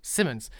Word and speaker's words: Simmons: Simmons oder Simons, wie wird das eigentlich ausgesprochen? Äh, J.K. Simmons. Simmons, ja. ja den, äh, den ja jeder Spider-Man-Fan kennt ihn.